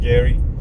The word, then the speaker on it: Garioch: what's called the Geary area, Garioch. It's spelt, but it's pronounced Geary. And there's a single riot van, single police van, that's just doing the rounds, basically, just making sure.